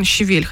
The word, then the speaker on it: щавель: Я всю жизнь говорила щавель, но, по-моему, все-таки правильно щавель, хотя мне это слово не нравится. Да я найду. Ты продолжай. – У меня просто открытая страница. Щавель. – Вот я же говорю. – Русское словесное ударение, так пишет, щавель. – Хотя мне щавель нравится больше. – И даже, можно сказать, неприятное. – Да. Но если что, я тоже щавель люблю больше, но щавель правильный.